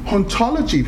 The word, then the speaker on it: hauntology: So hauntology, therefore, is a play on the idea of ontology. So in the French, the H is silent. In English, we hear haunt.